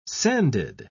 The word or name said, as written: sanded